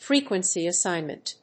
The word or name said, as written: frequency assignment